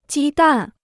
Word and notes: 鸡蛋: jī dàn

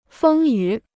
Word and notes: 风雨: fēng yǔ

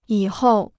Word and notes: 以后: yǐ hòu

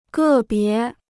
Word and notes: 个别: gè bié

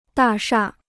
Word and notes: 大厦: dà shà

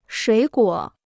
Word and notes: 水果: shuǐ guǒ

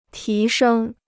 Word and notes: 提升: tí shēng

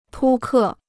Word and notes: 扑克: pū kè

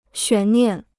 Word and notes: 悬念: xuán niàn

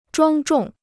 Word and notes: 庄重: zhuāng zhòng

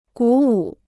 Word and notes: 鼓舞: gǔ wǔ

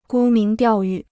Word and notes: 沽名钓誉: gū míng diào yù

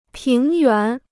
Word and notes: 平原: píng yuán

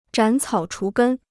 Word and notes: 斩草除根: zhǎn cǎo chú gēn

